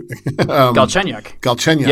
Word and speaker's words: Galchenyuk: And they just picked up Galchenyuk, if I pronounce his name right. – Galchenyuk.